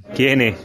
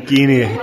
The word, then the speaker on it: Geaney: But first, the definitive pronunciation of his second name, Geaney, Geaney, yeah. I get that a lot actually. Guinea kind of annoys me to be honest, but anyway, I suppose phonetically it might be Guinea, but yeah, Guinea.